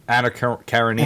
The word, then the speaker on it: Anna Karenina: Anna Karenina, how do you say it? – Anna Karenina? – Anna Karenina. – Karenina. – Karenina. – Yeah. – Thank you.